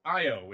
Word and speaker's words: io: Now, okay, I've always wanted to know, is it pronounced Io or is it pronounced Eo? – Oh, okay, so you can put the letter E-O for that. – So, yeah. So it's pronounced Io.